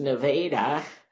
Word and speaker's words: Nevada: Nevada, pronounced Nevada, borrowed the spectacle of the other Nevada.